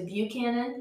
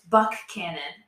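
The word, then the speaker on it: Buckhannon: I've noticed recently at work, people say Buchanan because that's how you say it, the one down in Virginia. But we have a K. – Right. That I keep forgetting. – And it's Buckcannon. Buckcannon.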